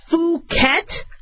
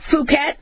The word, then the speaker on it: Phuket: I don't mean to say it, but if I mispronounce it, it's Phuket, right? One of the strains in the vaccine, Phuket. I got to remember not to do a short U.